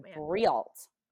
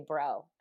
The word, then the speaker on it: Breault: But I my married name is Bro, but it's pronounced Bro, but it's spelled B-R-E-A-U-L-T. It looks like Brealt.